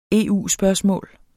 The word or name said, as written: EU-spørgsmål